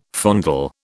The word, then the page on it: fondle: British English